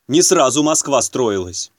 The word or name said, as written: не сразу Москва строилась